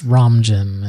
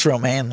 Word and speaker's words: Romijn: It's Romijn in my head, so. – I think it's just Romaine, like the lettuce.